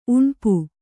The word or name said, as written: ಉಣ್ಪು